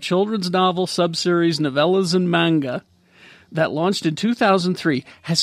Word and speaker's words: manga: It's a series of like books and manga and a whole bunch of different stories. – Is that how you say it? – It's manga. – Manga? – I've been saying manja.